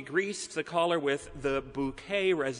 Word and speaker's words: Bucket: In the British made-for-TV comedy, Keeping Up Appearances, Hyacinth Bucket, who insists that her last name is pronounced Bouquet,